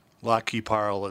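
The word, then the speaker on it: Lac qui Parle: Lac-qui-parle is how they pronounce it in Minnesota.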